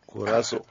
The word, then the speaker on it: Curaçao: Comment tu prononces? – Curaçao. – Curaçao. – Curaçao. – La vraie expression, c'est Curaçao. – Parce qu'ils ont dit bon, qu'on va nous aider à dire comment ça se prononce.